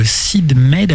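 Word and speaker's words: Syd Mead: Et aussi, c'était pour dire que Sid Mead, hein, c'est comme ça qu'on prononce, est décédé.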